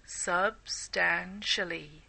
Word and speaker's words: substantially: Substantially is a difficult word to pronounce if you're not used to it, so let's practice it in sections. Sub-stan-shally. Sub-stan-shally. Substantially. Substantially. There, so I encourage you to continue practicing that and other long words, of course.